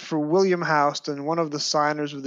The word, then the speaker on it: Houston: And his name was actually spelled with two N's, H-O-U-S-T-O-N-N. When they named the street after him, they misspelled it. – Right. – But that's why it's pronounced Houston and not Houston. So it looks like it's Houston Street, but it's actually Houston.